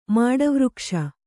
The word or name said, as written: ಮಾಡವೃಕ್ಷ